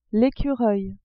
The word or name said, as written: ecureuil